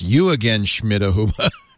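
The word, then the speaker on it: Jürgen Schmidhuber: And by the way, his name is pronounced You Again, Schmidhuber. according to him, interesting.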